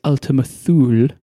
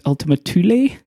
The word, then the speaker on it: Ultima Thule: I believe I called the name of the target that New Horizons was fast approaching as Ultima Thule, with a stupid voice. It's actually pronounced Ultima Thule.